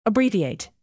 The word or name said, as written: abbreviate